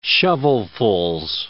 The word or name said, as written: shovelfuls